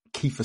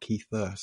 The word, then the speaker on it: Kiefer: It's Kiefer, not Keither. So just for the Keitherites, Sutherland stands. – Yeah, it's not a silent F, listeners, so don't get caught out.